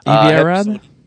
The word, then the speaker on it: Avi Arad: But Avi Arad, I'm sure I'm mispronouncing his name. – Avi Arad? – Thank you.